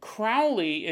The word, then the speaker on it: Crowley: He said, he goes, if you haven't recorded the episode yet, Crowley is pronounced like cow with an R. That might be the most, that might be the most confusing way Crowley. It's not Crowley. It's Crowley. I guess that rhymes with cow.